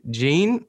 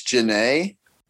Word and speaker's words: Jhené: Is it J- Jean or Jaheen Akio? Do you know? I believe it's Janae.